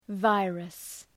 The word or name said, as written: virus